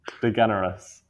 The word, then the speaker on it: DeGeneres: Right, anyway, so Reagan's top three bete noirs have been Catherine Tate, Paul Hollywood, and Ellen DeGeneres.